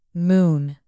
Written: moon

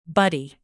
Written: buddy

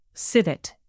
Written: civet

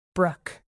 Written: brook

